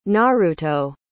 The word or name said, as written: naruto